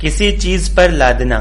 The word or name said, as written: लादना